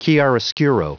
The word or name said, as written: chiaroscuro